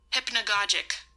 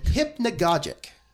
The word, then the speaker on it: hypnagogic: So the final word I picked out a couple, like 20 minutes ago, and had forgotten how to pronounce it. So nice. – Phone, please help me out. – Hypnagogic. – Hypnagogic? – Hypnagogic. – Hypnagogic. – Hypnagogic. – H-Y-P-N-O. – H-Y-P-N-A-G-O-G-I-C. – Hypnagogic. H-Y-P-N-A-G-O. – Hypnagogic. Don't you be typing it in your computer. – No, I'm gonna spell it out so I can see it. – Hypnagogic. – Okay, spell it again. – H-Y-P-N-A. G-O-G-I-C. – Hypnagogic.